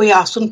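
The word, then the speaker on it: Iáson: A taky se nevyslovuje jako, jako s tím hebrejským způsobem, ale vyslovuje se jako Jáson, píše se I-O, jo, jako tím, tou výslovností tak, jak je, tak to I-O se čte jako já, tak jako se třeba v Byzanci a podobně tímto způsobem se mluvilo, to jsou poloslovanský, slovanský řeči.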